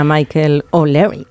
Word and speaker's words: Michael O'Leary: Hombre, que se lo digan a Michael O'Leary.